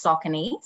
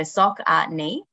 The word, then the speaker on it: Saucony: Fun fact is it is actually pronounced Saucony, so Saucony.